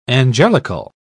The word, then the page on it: angelical: British English